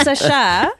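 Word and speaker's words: Sacha: It's pronounced Sasha.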